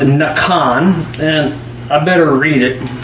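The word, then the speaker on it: Nakan (נקם): Nakan. Like N-A-W-K-A-W-N is how phonetically how it sounds.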